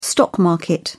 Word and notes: stock market: British English

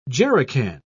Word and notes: jerrican: British English

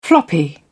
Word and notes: floppy: British English

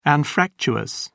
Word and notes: anfractuous: British English

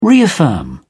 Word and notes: reaffirm: British English

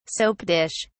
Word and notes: soap dish: British English

